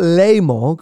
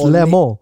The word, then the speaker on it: Le Mans: It's not Le Mans, because it's, I don't think, I am not French, but it's not Les Mans, and you don't pronounce the S at the end. It's Le Mans.